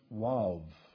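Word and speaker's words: waw: The second letter is really what we call O, but in the Hebrew language, it's pronounced Wav.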